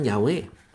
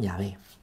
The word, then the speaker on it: Yahvé: Yahweh, Yahvé. Los hebreos hoy lo pronuncian así, que vienen de esa pues de esa tradición antigua. Lo pronuncian Yahvé, Bueno, por si alguna vez discutido esto con alguien.